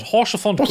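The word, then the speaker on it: Haurchefant: Pronounce it like it's French, he says. Lord Horchefont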